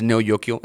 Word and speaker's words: Neo Yokio: Lo dije bien, no es Tokio, es Neo Yokio, es el título.